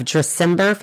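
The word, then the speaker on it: Dressember: And correct me if I'm wrong, of Dresember Foundation. Am I saying that correctly? – Yeah, that's right.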